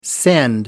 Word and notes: send: British English

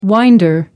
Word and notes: winder: American English

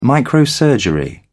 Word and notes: microsurgery: British English

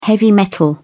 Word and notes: heavy metal: British English

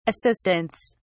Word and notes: assistants: British English

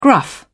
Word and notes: gruff: British English

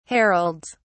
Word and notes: heralds: British English

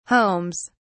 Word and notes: homes: British English

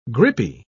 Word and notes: grippy: British English

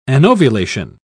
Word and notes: anovulation: British English

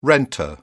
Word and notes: renter: British English

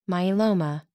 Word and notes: myeloma: American English

